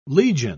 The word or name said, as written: legion